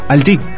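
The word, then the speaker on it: Halty: Alti. – Sí, sí, se inventó que es Alti, que es francés, vasco francés.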